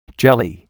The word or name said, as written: jelly